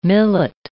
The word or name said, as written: millet